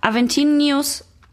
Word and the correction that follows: Aventinus: Ach nee, ich dachte gerade, der heißt Aventinius und ich habe das falsch gesagt. Der heißt Aventinus.